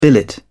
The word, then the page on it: billet: British English